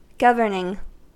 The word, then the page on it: governing: American English